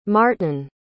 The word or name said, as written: maartyn